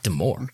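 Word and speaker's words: D'Amore: It's not Scott DeMore, right? It's Scott DeMore. – Scott DeMore. That's how it's always been.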